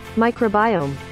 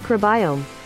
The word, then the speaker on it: microbiome: Microbiome. – Microbiome. – Microbiome. – Is that right? – Microbiome. – Yeah. – Okay. So there you go. – Microbiome.